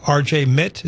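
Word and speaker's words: RJ Mitte: R.J. Mitt, is that how you pronounce it? – Yeah.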